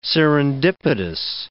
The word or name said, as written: serendipitous